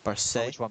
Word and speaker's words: Marseille: Marseille.